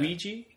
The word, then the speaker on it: Ouija: As Greg Gaines, Olivia Cooke, uh, who's from the Quiet Ones, the Signal, and I think it's pronounced is it Ouija or Ouija? Like a Ouija board. Ouija board. Yeah. It's yeah. Ouija. Yeah. – Ouija. Even though it's spelled Ouija.